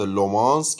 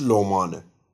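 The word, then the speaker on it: Le Mans: مسابقات لومانس که تلفظ غلط و اشتباهی بوده درستش لومانه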